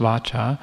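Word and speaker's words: vācā: So literally the word in Pali for speech is vacha, which interestingly is connected to, it's with a V, it's pronounced vacha, connected to the Latin vox for voice.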